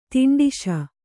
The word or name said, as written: ದಾಂಟಿಸು